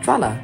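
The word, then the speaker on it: Drahla: Ich glaube, Drawler spricht man es aus, ne? They're British, so we say Drawler.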